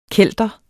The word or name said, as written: kelter